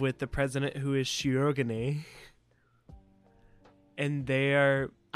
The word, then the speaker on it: Shirogane: If you want to say it how they say it in the dub, it's Shirogane. – Shirogane. – They kind of do the R more like a D.